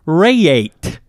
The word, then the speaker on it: Rayet: And then R-A-Y-E-T. What do you got for that? – R-A-Y-E. Raye. – Oh. – George Raye. – Oh, si. – Raye. – Oui. – Yes.